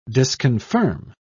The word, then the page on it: disconfirm: British English